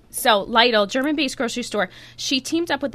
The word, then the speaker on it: Lidl: Lidl, L-I-D-L. There's a German grocery store. Lidl, yes.